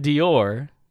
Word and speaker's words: Dior: Christian Dior. I'm sure I'm pronouncing that correctly. – Yes.